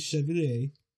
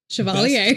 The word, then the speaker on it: Chevalier: It was nominated for Best Actor for Maurice Chevalier. No. – Chevalier. – Best, yeah, no, I know. I purposely mispronounced it.